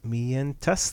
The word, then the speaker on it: Mientas: Mientas. Mientas, that's right. – That's good pronunciation.